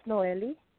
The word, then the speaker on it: Nohely: My name is Noelle. – How do you spell that, please? – N-O-H-E-L-Y.